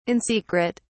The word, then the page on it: in secret: British English